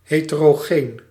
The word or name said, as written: heterogeneous